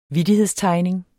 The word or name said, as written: vittighedstegning